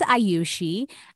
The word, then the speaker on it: Ayushi: So the director asked me, he's like, how do you say your name? So I said, my name is Ayushi. And I was saying it slowly.